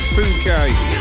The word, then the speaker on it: Phuket: And let's not read it out the way it's spelt. We've not long started, have we? So, yeah, I think it's Phuket. We'll leave it at that.